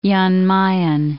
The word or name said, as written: Jan Mayen Island